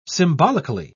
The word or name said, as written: symbolically